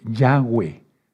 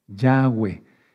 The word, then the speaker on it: Yahweh: La letra Bav sonaba como W mucho antes, o sea, en el paleo hebreo. Y ese es el hebreo que vamos a hablar cuando venga Yahshua. Por eso el nombre correcto es Yahweh. Yahweh.